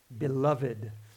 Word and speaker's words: beloved: I put that in the old style, right? Beloved. We have to add the little that little mark over the last E so that we know to pronounce it.